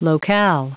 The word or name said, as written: locale